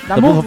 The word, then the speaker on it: d'Amour: Damour francés. Y porque se pronuncia así, discúlpenme.